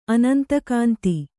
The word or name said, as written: ಅನಂತಕಾಂತಿ